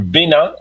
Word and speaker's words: Benin: But first, today we're headed to Benin, or Benin, as it's pronounced in French, a country in West Africa with deep history and powerful connections to the Black diaspora.